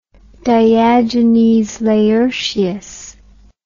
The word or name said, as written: diogenes laertius